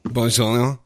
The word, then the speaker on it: bourguignon: Well, I have tried to study some French pronunciation recently, so I'm going to go with cut the second half of the word off and make it real fluid. So beef bourguignon. – Bourguignon. All right. Good.